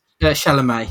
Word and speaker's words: Chalamet: Is it Timothy Chalamet? Is that how you say it? – Chalamet, yeah.